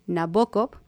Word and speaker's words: Nabokov: De hecho, se recomienda que sea pronunciado como Nabokov.